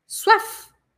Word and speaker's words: soif: Suaf, suaf, suaf. Eu tenho que falar esse F, tá? Suaf se escreve soif, mas eu falo suaf, porque sempre que tem OI em francês, eu vou falar wa.